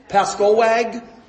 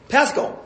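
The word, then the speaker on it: Pascoag: Pascowag? How do you pronounce it? – Pasco. – Okay, so the G is silent, right?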